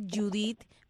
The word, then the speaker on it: Judith: Tengo también mi página de Facebook, que me puedes buscar, Judith. Mi nombre se escribe J-U-D-I-T-H.